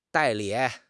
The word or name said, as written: 代理